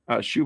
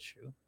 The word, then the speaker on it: Shue: Shue, by the way, is spelled S-H-U-E. – Okay. – Oh, get the hell out of here, you freaking blocking puppet. – Like Elizabeth Shue. – Who? – Elizabeth Shue, actress.